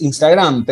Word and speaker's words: Instagram: ¿Qué Claro, porque en realidad todos decimos Instagram, pero en realidad es Instagram.